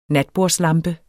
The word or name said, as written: natbordslampe